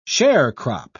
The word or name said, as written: sharecrop